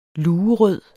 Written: luerød

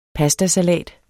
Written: PASTASALAT

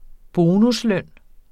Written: bonusløn

